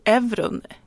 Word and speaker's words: Euron: Ja, och för, för den som kanske kände igen skådespelaren där som spelar den här djuren, euron, euron Greyjoy. Jag vet inte hur man uttalar det. – Om man ska, uttala, Euron, om man ska uttala det som man uttalar alltså, pengarna.